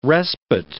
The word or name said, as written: respite